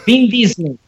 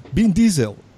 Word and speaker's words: Vin Diesel: El español se dijo Vin Diesel. Y no, es Vin Diesel. Así tal cual.